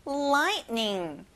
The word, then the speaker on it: lightning: 번개, lightning. 여러분, T가 보이지만 발음이 안 나요. Lightning.